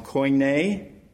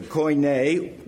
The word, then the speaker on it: Koine: This Greek world language became known as the, and Pastor Carlson, you can correct me if I say this wrong, Koine. In other words, I didn't do it very good, actually. The Koine, which means the common language.